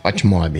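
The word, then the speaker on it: FotMob: O Footmob, como, como, é assim que você pronuncia? Footmob. Então, como ele tem um O só, eu pensei que era Footmob. Footmob, é.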